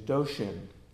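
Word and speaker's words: dōshin: But in China and in Japan, this was translated as, to use the Japanese pronunciation, doshin, the mind of the way.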